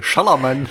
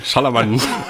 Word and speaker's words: Shyamalan: Oder man macht es einfach wie Wolfgang im Schmidt, Shyamalan. So. Was ja auch schon so mies geworden ist. – Shyamalan. – Shyamalan. – Also wie Ballermann im SCA.